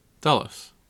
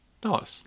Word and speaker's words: Dulles: Dulles. I think I am. – Dulles. – Right? – Dulles.